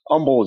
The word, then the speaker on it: Umbel: Umbel is good.